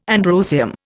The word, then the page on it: androecium: Indian